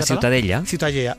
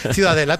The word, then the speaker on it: Ciutadella: ¿cómo se pronuncia en La Ciutadella. – Ciutadella. Bueno, pues Ciudadella también en castellano.